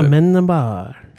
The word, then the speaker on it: Amenábar: Amenábar.